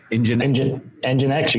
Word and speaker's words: Nginx: It looks like F5 bought Nginx. Did I say that right? – Nginx, you got it.